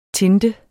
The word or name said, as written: tinte